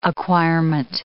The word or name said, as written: acquirement